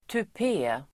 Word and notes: tupé: [tup'e:]

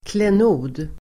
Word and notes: klenod: [klen'o:d]

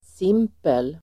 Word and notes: simpel: [s'im:pel]

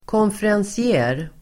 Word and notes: konferencier: [kånferansi'e:]

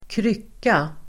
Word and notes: krycka: [²kr'yk:a]

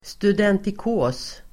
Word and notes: studentikos: [studentik'å:s]

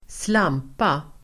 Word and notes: slampa: [²sl'am:pa]

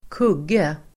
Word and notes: kugge: [²k'ug:e]

